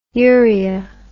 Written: uria

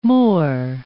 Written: moor

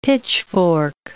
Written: pitchfork

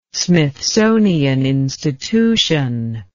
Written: Smithsonian Institution

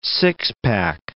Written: six-pack

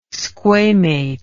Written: squamate

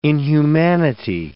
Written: inhumanity

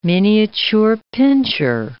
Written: miniature pinscher

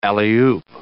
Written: alley-oop

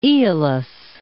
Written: Aeolis

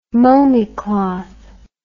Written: momie cloth

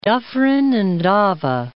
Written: Dufferin and Ava

